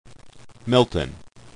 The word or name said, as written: MLton